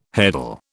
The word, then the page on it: heddle: British English